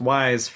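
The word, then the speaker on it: Weyes: It's W-E-Y-E-S, but it's pronounced Wise for reasons I don't fully understand.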